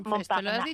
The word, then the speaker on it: Montparnasse: Este lo he dicho bien, ¿no? – Montparnasse. Este lo has dicho très français, mejor, ha sonado, ¿no?